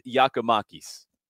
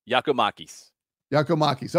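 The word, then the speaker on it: Giakoumakis: How do I pronounce his name? – You're not the only one. This is going to be an ongoing thing. He said it at his introduction. You guys will get to know how to say my name if I keep scoring goals, and hopefully he does. Yorgos Giacomakis. – Giacomakis. Okay. All right.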